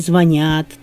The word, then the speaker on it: звонят: А правильно: он звонит, они звонят, ты позвонишь, мы созвонимся.